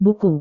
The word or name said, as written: buku